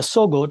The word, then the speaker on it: SOGO: So when you come to, for example, sonic logo, I call it a SOGO to rhyme with a logo.